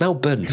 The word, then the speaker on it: Melbourne: It's Melbourne, for God's sake. Like, that's my final thought. Say it properly, okay?